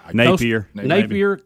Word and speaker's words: Napier: Liberty's Hugh Freeze, Louisiana's Billy Napier. Am I saying that right? – Napier. – Yeah.